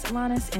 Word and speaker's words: Alanis: My name is Alanis, and as always, you can find me on Instagram at Alanisella.